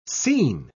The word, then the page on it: sene: British English